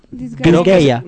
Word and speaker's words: Disgaea: Ahí salió ¿cómo se dice? Disgaea. – Disgaea. No, creo que se lee así completo.